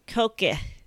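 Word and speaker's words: Cogadh: She says that the D and the G should probably be switched, which would be pronounced Koke. Koke.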